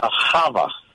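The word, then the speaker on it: Hava (חוה): Hava.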